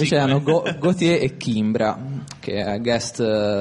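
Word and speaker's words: Gotye: Allora, ci ascoltiamo una canzone recente del 2011 di Gautier, si pronuncia così, perché anche se è scritto in modo diverso, ricorda il nome Gaultier francese, è scritto Gautier però con la Y.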